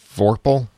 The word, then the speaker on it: Vorpal: Now, so the sword, the Vorpal sword, mm-hmm.